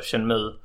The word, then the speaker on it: Shenmue: Jag köpte PlayStation 4 nu för att Shenmue. Många står sig på att det säger Shenmue, men det heter ju då Shenmue.